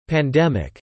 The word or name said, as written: pandemic